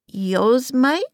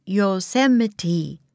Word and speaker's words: Yosemite: "Yosemite?" said Annie. – Jack laughed. – "No, it's pronounced Yosemite," he said.